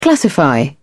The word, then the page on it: classify: British English